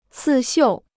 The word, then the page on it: 刺绣: cì xiù